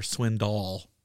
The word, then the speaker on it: Swindoll: Is it Swindle or Swindoll? How do you say it? – Swindoll.